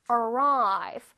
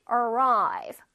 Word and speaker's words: arrive: arrive 발음 다시 해볼게. arrive. 자, r가 두 개 있으니까 혓바닥 맨 끝에 있어야지. arrive. arrive.